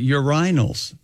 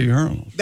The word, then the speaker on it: urinals: It's a depressing England, in England, they pronounce it urinals. – Really? – Instead of urinals. – Now, that's wrong.